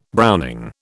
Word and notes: browning: British English

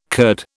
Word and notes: Kurd: British English

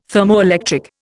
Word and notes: thermoelectric: Indian

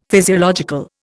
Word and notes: physiological: Indian

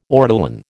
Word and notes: ortolan: American English

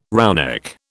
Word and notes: roughneck: British English

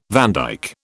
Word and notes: vandyke: British English

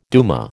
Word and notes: duma: American English

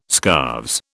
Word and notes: scarves: British English

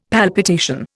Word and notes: palpitation: Indian

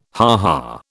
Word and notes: ha ha: British English

